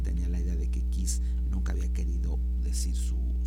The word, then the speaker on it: Kees: Si ustedes luego quieren buscar más información o música del maestro, tienen que poner K-E-E-S, que es Boeke, así se escribe su nombre, que es Boeke, Kiss Bech.